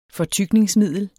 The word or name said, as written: fortykningsmiddel